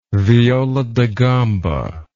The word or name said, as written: viola da gamba